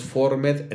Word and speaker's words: Formed: Y el año pasado iniciamos Formed en español. Es que Formed se pronuncia Form en inglés,